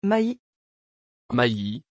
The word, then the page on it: Mailies: French